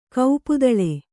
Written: ಕೋಪದಾಳು

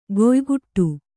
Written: ಗೊಯ್‍ಗುಟ್ಟು

